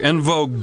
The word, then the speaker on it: En Vogue: En vogue. En vogue. Don't let go. – Did you call them En Vogue? – En Vogue. – That's the correct French pronunciation.